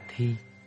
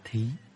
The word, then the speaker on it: thí: Chữ thí còn được đọc là thi. Thi có nghĩa là làm, thi hành. Nhưng chữ thí ở đây được đọc là thí, tức là hiến tặng.